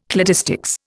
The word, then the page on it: cladistics: Indian